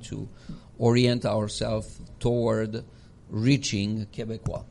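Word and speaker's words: Québécois: Okay, maybe explain what Quebecois is. Because they might be thinking it's Quebecers. But not. – Quebecois is the right pronunciation of Quebecers.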